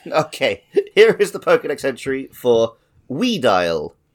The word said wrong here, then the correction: Weavile: I just pronounced that wrong. Weavile.